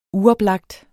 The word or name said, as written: uoplagt